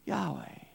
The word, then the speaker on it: Yahweh: In Hebrew, it's pronounced Yahweh.